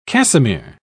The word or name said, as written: cassimere